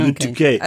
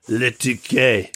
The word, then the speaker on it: Le Touquet: Le Touquet. Did I say that right? – That was pretty good. Okay. Le Touquet. – Okay. – Le Touquet.